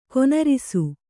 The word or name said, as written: ಘನರಸ